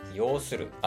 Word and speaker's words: 擁する: 抱く、抱える、要する。あ、要するって読むこれ。